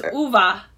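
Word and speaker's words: Ove: You're reading Man Called, it's pronounced like Uva or Ova. – Yeah, it's Uva.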